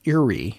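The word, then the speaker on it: Ewry: Some polio sufferers have gone on to athletic careers, including the most winningest track and field Olympian, Ray Urey, I'm not sure I'm saying that right. It's E-W-R-Y.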